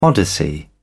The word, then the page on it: odyssey: British English